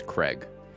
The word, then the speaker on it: Craig: Just a pronunciation note there. It's Craig.